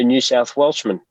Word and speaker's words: New South Welshman: my fellow New South Welshman. Is that how you say it? – That certainly is.